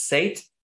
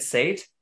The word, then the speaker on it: Seth: I understand that Seth is also a first name, but where I come from, Seth is pronounced differently. It's pronounced as Sate, so it's Sid Sate. However, as an artist name, I'm totally fine with people calling it Sid Seth or Sid Sate.